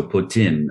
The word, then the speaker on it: poutine: So it's not po, it's poo, and you drop the E on the end. So poutine or poutine. So you try to put a little French flair into it and it will get you a better plate.